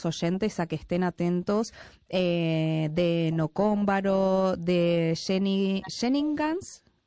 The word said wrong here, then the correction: shenanigans: ¿pronuncié? – Shenanigans. – Ah, perdona. – Es una perdona. – Palabra de Irlanda céltica.